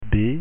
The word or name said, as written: b